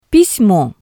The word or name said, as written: pismo